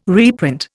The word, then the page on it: reprint: Indian